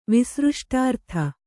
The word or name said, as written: ವಿಸೃಷ್ಟಾರ್ಥ